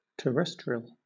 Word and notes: terrestrial: British English

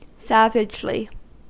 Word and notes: savagely: American English

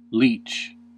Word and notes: leach: American English

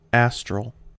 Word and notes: astral: American English

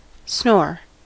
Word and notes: snore: American English

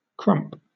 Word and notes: crump: British English

